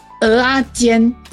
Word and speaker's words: 蚵仔煎: 所以刚好的，OK 的。我等一下，我等一下还是要去研究一下蚵仔的各种料理，或是蚵仔煎还是蚵仔米刷这样子。哎，不过你们说讲这样子不够，到底是蚵仔煎对不对？ – 对，嘴巴扁扁的蚵。